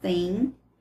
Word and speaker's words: thing: The only thing, eu deixei o TH para lembrar do, da pronúncia, o thing, that's right.